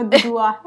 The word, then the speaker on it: Duduá: Dudua, con acento en la última A. En la última A, que es la única A.